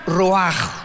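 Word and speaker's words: ruach (רוח): Y la palabra para espíritu en el versículo 29 es la palabra, se escribe ruach, como suena, con R de Raúl, CH al final, ruach, y se pronuncia ruach.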